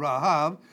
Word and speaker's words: Rahav: The one is, of course, Rahav, which is which has the H sound, Rahav, and the B sound is a V sound, so Rahav would be the clear pronunciation.